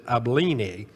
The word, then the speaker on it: Abilene: I say Abilene. I listened to a pronunciation of this week. It said Abilene. If you're from Texas, you probably say Abilene.